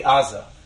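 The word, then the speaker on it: Aza (עזה): But ayin in Hebrew is correctly pronounced with a guttural sound, ayin. That's where the G comes from, right? So that's why Gaza is really Aza.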